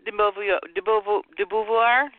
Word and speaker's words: de Beauvoir: Simone de Beauvoir, help me with the pronunciation, Lisa. De Beauvoir, right? – I think that's correct.